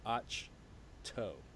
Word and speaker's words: Ahch-To: But that changes when she arrives on, and I just learned this pronunciation today, it's Ahch-To. – Ahch-To. – Not Ahch-To.